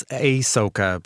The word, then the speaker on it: Ahsoka: That's Ahsoka. You can stream now episodes every Wednesday on Disney. Plus, Did I pronounce that right? – I think it's Ahsoka.